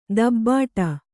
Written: ದಬ್ಬಾಡು

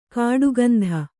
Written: ಕಡುಗಂದಿ

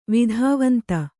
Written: ವಿಧಿವಂತ

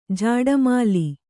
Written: ಝಾಡುಮಾಲಿ

